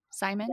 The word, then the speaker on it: Simon: Simon? – Yes, you did.